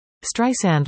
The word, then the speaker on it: Streisand: After famously calling Apple CEO Tim Cook to get Siri to pronounce her last name right, no Z sound, Streisand learned to override auto-correction with some advice from her assistant.